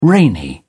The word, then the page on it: rainy: British English